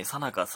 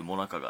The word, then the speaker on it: 最中: えさなか、最中、もなかがあって、えー、読み方によって少し意味が異なるので注意しましょう。